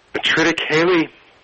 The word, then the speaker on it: triticale: Triticale is a very, it's a very ancient grain. I'll tell you exactly what that is. It's pronounced triticale.